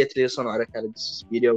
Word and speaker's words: Suspiria: Mas Suspiria ou Suspira? Suspiria, né? – Suspiria.